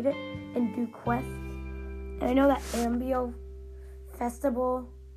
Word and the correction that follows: Amiibo: Oh, it's Amiibo Festival. I've always been pronouncing it wrong then.